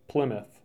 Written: Plymouth

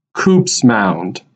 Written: Coops Mound

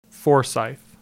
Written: Forsyth

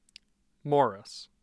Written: morris

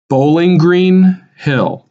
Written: Bowling Green Hill

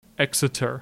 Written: Exeter

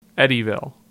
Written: Eddyville

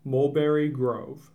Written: Mulberry Grove

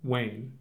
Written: Wayne

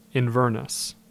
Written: Inverness

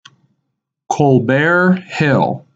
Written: Colbert Hill

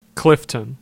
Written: Clifton

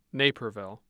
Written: naperville

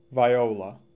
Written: Viola

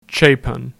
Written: Chapin

